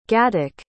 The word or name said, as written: gatik